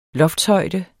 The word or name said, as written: loftshøjde